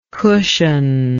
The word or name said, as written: cushionlike